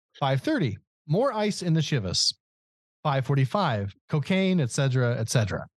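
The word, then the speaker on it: Chivas: 3:50, another glass of Shivas. I don't know why I said it that way that time. – That's how it's said. – Is it? – Yeah. – I wasn't sure. It sounded like Shiva, like we're morning.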